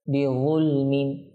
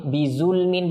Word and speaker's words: بظلم: এখানে আমরা তিন আলিফ কমপক্ষে মত করবো, বিয়ুলমিন, বিয়ুলমিন, বিয়ুলমিন। দেখুন এটা ও ও ও, বিয়ুলমিন, বিয়ুলমিন। আপনি বিজুলমিন বলেন, ভুল হবে, কেমন। আবার যা যেটা আছে বিজুলমিন, এটাও ভুল হবে।